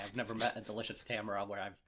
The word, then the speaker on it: Tamara: Tamara is how that's pronounced in that show.